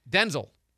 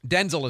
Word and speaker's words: Denzel: How do you say it? It's Denzel. Denzel is how we're supposed to say it now.